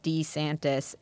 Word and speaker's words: DeSantis: They claim that it was his wife who urged him to start pronouncing his last name Duh instead of D. – So it's Ron DeSantis, not Ron DeSantis? – Well, apparently it's DeSantis, and she is the one who urged him to begin pronouncing it Duh.